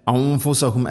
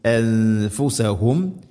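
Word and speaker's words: അൻഫുസഹും (أنفسهم): അംഫു അല്ല അംഫുസഹും അല്ല അൻഫുസഹും യസ്ലിമൂൻ.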